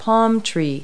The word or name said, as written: palm tree